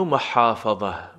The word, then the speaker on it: محافظه: ایو محافظه. محافظه حا از وسط حلق حلقی. محافظه زید دال. این زای دست دار کمی حجیم تلفظ میشه، نک زبانی ولی حجیم.